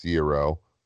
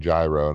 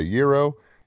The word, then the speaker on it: gyro: It is pronounced gyro. I just wanted to make that point. I had a buddy of mine kept saying gyro, and I said, nope. I've learned that it is a gyro.